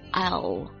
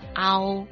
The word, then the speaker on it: I'll: 歌词里面多次出现的这个 I'll，它的发音就是 I'll，听起来有点像 ow，实际上是 I'll。